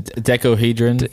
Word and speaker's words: decahedron: Decahedrohydron. – Decahedron, I think is what it's called.